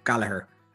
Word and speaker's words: Goligher: Today, we're going to talk about Kathleen Goligher, but it's an awkward one because it's spelled G-O-L-I-G-H-E-R. So I think we're all pronouncing it a bit differently, but we kind of think it might be Gallagher, but just an anglicization of it.